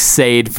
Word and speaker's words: Sade: I honestly knew nothing of, you're saying Sade is how you say it? – That's how you pronounce it. Yeah. – Apparently, I've said it like Sade for, probably on the show, I've called it Sade before.